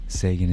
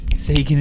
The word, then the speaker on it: Seguin: Seguin is two syllables. – Bring back Tyler Seguin. – Seguin is two syllables.